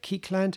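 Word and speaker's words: Kékéland: Bridget Fontaine, Keekland, is that how you say it? Keekland. – Oh, yeah.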